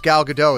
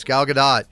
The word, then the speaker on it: Gal Gadot: And don't at me that it's Gal Gadot. It's Gal Gadot. Israeli, not French. That's how it's pronounced.